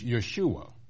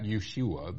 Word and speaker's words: Yeshua: Actually, in Hebrew, it is Yeshua. Yes, just like that Yeshua, the one we call Jesus Christ.